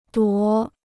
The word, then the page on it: 夺: duó